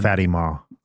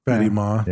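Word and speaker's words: Fátima: It's actually pronounced Fatima. – Fatima.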